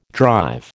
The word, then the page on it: drive: American English